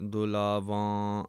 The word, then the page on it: dulavan: Punjabi